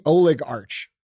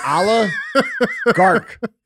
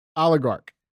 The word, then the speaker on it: oligarch: No, that would be Oleg Arch. – That's what I said. – No, you said Oleg Arch. Bro, this is Oligarch. – Oligarch.